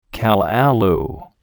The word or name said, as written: callaloo